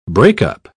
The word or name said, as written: breakup